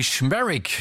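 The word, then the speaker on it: Szmierek: Anthony Schmerick, genau so wird er ausgesprochen,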